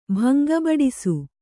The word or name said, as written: ಭಂಗಬಡಿಸು